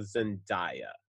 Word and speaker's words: Zendaya: I would redo this movie, but I would change out Jonathan Taylor Thomas for like a Zendaya.